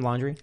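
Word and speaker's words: Laundrie: Did I say his name? Laundry? – Oh, yeah.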